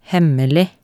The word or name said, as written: hemmelig